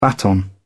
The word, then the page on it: baton: British English